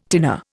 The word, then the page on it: tinner: Indian